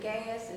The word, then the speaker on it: Gaius: Gaius? Is it? Am I saying it right? – Yeah.